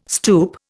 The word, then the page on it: stoep: Indian